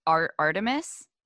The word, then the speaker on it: Artemis: So the first things I was thinking were when you started getting to Artemis, is that how you say it? – Yeah.